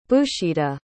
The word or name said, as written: bhooshita